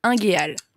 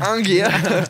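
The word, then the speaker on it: unguéal: Ingéal. – Waouh. – C'est l'inverse de désigual. – Ingéal. Ingéal. – Ça s'écrit U-N-G-U-E-A-L.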